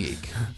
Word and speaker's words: Jake: Yake? Oh, it's definitely Yake. No, we've been saying that wrong for years.